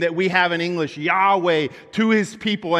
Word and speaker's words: Yahweh: That word Lord right there are those four letters, YHWH, what we pronounce Yahweh.